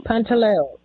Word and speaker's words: Pantaleo: Pantaleo, P-A-N-T-A-L-E-O.